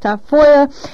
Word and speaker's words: Tafolla: It's spelled actually T-A-F-O-L-L-A, but it's pronounced Tafoya.